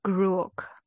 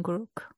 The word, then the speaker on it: Gruoch: Ich glaube Gruoch. Also das CH wird, glaube ich, im Gälischen K ausgesprochen. Gruoch.